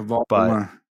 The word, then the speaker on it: Baudelaire: Baudelaire. I don't know if you know how to pronounce that. – Yeah, Baudelaire.